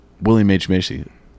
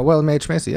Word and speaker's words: William H. Macy: The only person who should have stayed black and white the whole time is William H. Macy.